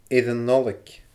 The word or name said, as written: air an Nollaig